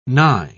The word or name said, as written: nigh